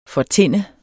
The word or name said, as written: fortinnet